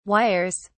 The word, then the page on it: wires: British English